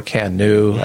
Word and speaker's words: Kano: I do like the Kano model, or Kanu. I've heard it pronounced both ways,